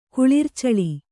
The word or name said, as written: ಕುಳಿರ್ಚಳಿ